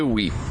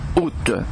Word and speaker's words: août: Eh oui, parce qu'il semblerait qui viennent d'émettre un genre de récapitulatif, ce qu'ils appellent un récapitulatif, précisant que le mot se prononce « ou » et non « out », ou encore moins « à ou ».